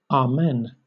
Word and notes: amen: British English

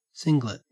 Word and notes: singlet: Australian English

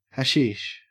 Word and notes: hashish: Australian English

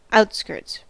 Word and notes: outskirts: American English

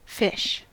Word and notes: fish: American English

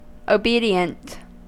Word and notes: obedient: American English

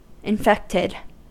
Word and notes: infected: American English

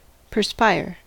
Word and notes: perspire: American English

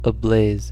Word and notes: ablaze: American English

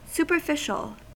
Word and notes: superficial: American English

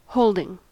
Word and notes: holding: American English

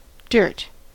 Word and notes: dirt: American English

